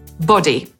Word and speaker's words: body: I Brytyjczycy wymawiają to słowo podobnie, bo po brytyjsku ciało to body.